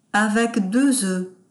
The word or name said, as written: avec deux oeufs